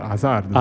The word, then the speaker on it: Hazard: Alla belga francese, diciamo, come si pronuncia Asar- Hazard? – Hazard. – senza D, credo.